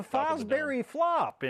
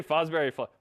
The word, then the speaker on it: Fosbury Flop: With the Fosbury flop in play. – Yeah. You have to say it like that. No, you can't say Fosbury Flop. The Fosbury Flop. Like a little nasal.